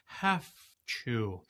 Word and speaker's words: have to: Don't pronounce it like that. The proper pronunciation sounds like it has an F sound in it. Have to.